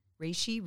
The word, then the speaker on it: reishi: So if if there is is there one thing like I saw you posting about reishi? Am I saying it right? – Reishi, Reishi.